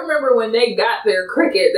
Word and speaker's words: Cricut: But, yeah, no, it's pronounced Cricut.